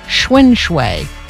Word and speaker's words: xuanxue: I am thinking the first part of the word is pronounced like the bicycle. And then, I think you can do shui pretty easily. Shwen shui. Now, it's spelled X-U-A-N-X-U-E. So it's counterintuitive for those of us who think in English.